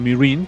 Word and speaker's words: Meereen: Bueno, Meereen, que es como se pronuncia...